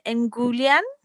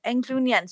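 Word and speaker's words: englyn: And then he heals him with an Engulian. Engulian, do you know what that is? – No. – I think I'm saying it wrong. Englunian. So it's poem as well. So I think it's like Eglwys.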